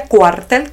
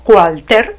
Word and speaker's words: cuartel: Hay que decirle a Miguel Díaz Canel, señoras y señores, que cuartel se pronuncia cuartel con L final y no cualter como insiste en hacernos creer que se dice.